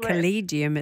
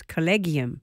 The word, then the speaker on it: collegium: Now, I got it wrong. I said collegium, it's collegium.